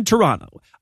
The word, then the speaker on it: Toronto: God Awful Movies Live in Toronto. Apparently, you're not supposed to pronounce the second T, and locals get Canadianly upset when you do.